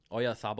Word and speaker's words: Oyarzabal: It was Oyarzabal or something. – Oh, the guy with a weird name. – Yeah, it's a Z in his last name, but they pronounce it TH.